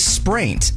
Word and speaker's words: spraint: What is spraint? S-P-R-A-I-N-T.